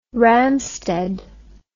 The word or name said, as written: Ramstedt